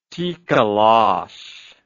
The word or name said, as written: tikoloshe